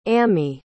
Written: ammie

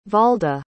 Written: Walda

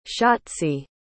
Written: schatzi

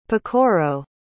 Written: pacorro

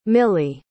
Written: milly

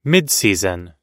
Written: midseason

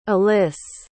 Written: alyss

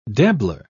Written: dabbler

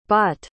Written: bot

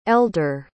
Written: ellder

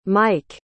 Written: maik

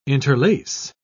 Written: interlace

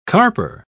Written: carper

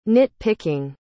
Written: nit-picking

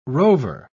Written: rover

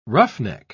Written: roughneck

